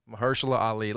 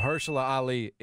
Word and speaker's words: Mahershala Ali: Mahershala Ali. Let me just say that. Because now he's on your radar. – Mahershala Ali is the next Blade.